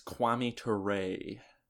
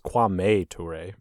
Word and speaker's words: Kwame Ture: True or false, Stokely Carmichael's new name is Kwame Ture. – I think you pronounced it wrong. It's Kwame Ture, but yeah.